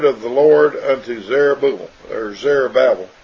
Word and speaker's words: Zerubbabel: This is the word spoken of the Lord Jehovah unto Zerubbabel, saying, and Zerubbabel, it's pretty much pronounced Zerubbabel is the way it's supposed to be pronounced.